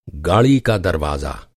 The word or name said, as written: दरवाज़ा